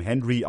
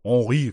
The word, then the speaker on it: Henry: Da habe ich den Engländer William Henry auch Henri genannt, also mit französischer Betonung.